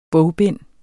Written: bogbind

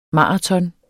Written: maraton

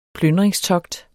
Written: plyndringstogt

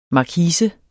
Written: markise

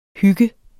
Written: hygge

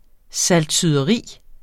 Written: saltsyderi

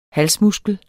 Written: halsmuskel

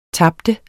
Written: tab

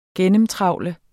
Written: gennemtrawle

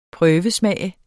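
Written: PRØVESMAGE

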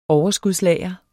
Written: overskudslager

